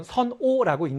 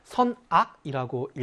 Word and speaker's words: 선악: 나쁜 것, 좋은 것과 나쁜 것, 선한 것과 악한 것, 일 때는 선오라고 읽는 것이 아니라 선악이라고 읽어야 되겠죠. 잘못되고 나쁜 행동을 오행이라고 읽는 것이 아니라 악행이라고 읽어야 되겠죠.